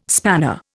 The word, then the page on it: spanner: Indian